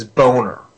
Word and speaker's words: Boehner: His name is Boehner. – Oh, Boehner. Boehner. – Yes. Spelled B. How do you spell it? – B-O-E.